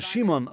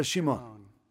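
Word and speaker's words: Shimon (שמעון): Auf Hebräisch wird er Shimon ausgesprochen. Sagen Sie alle Shimon.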